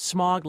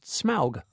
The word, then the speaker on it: Smaug: I always thought it was pronounced smog, like the Los Angeles pollution, but it's not. It's pronounced smaug.